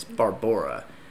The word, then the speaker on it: Barbora: And it just so happened she had a friend, you know, her friend from Prague, and her name was Barbara. But it, the way it's spelled and the way you're supposed to say it, it's Barbora. – Oh. – B-A-R-B-O-R-A. – That's very exotic.